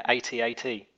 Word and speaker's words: AT-AT: Yeah, AT-AT.